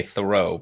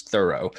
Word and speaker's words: Thoreau: and I'm trying to remember the accent has slid over the generations from first to second syllable. So now we say Thoreau, but I guess back then it was Thoreau.